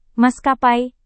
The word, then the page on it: maskapai: Indonesian